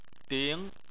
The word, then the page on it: ding: 2